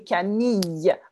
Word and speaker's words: Camille: Et en français, ce n'est pas Camille, c'est Camille avec un Y sound. D'accord ? Camille. D'accord ? Camille. Il n'y a pas de L, on ne prononce pas le L, parce que I-L-L-E égale Y, d'accord ?